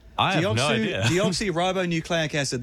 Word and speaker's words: deoxyribonucleic acid: All right, DNA, deoxynucleic? How do you say it? Deoxyribonucleic acid. There it is.